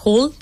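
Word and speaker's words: Hult: Hull International Business School en Boston, Estados Unidos. ¿Está bien la pronunciación, compañero? Correcta.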